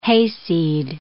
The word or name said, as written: hayseed